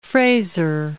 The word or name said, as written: Fraser